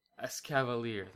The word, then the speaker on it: Escavalier: Escavalier, that's how it's pronounced. Fucking some of these Pokemon, man. – It's like Cavalier and Escargot.